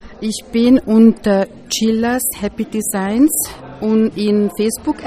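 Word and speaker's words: Csillas: Und damit wir es jetzt ganz sicher finden, Chillas spricht man es aus, aber geschrieben wird es Cäsar Siegfried, Ida Ludwig Ludwig, Anna Siegfried. Happy Designs.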